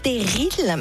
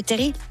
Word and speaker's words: terrils: Bonne prononciation, parce qu'on ne dit pas en effet des terrils, mais des terrils. – Moi j'aurais dit terrils, c'est vous qui m'avez expliqué. – Oui, mais moi je ne savais pas avant de venir ici. Hein. Le L en fait ne se prononce pas comme on ne dit pas un fusil, mais un fusil.